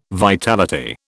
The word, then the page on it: vitality: British English